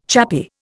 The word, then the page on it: chappy: Indian